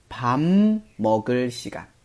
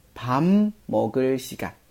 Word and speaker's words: 밥 먹을 시간: pop 下面这个 b 的收音，如果遇到后边儿 m 开头的时候呢，这个 b 会被同化成嗯发嗯的音，所以我们在读这个吃饭的时间的时候呢，我们要读成 pam 먹 을 시 간， pam 먹 을 시 간，啊，这边需要注意。